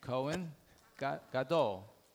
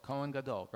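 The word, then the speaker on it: Kohen Gadol: How do you say it in Hebrew? Cohen, like Mel Cohen. Cohen Gadol. Gadol. Cohen Gadol.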